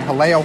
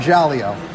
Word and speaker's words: Jaleo: I think I'm saying that right. Haleo, Haleo, Haleo. At least I'm not calling it Jaleo.